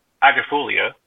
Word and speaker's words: agrifolia: We have we have that here, and we also have the straight species of um, the Quercus uh, agrifolia. – Mm-hmm. Did I say that right? – Yeah.